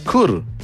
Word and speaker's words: Chur: Se pronuncia Chur, pero se escribe Chur.